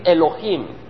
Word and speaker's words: Elohim: Vamos a decir Elohim. – Elohim. – Esa es la palabra hebreo, Elohim.